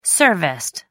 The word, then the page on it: serviced: American English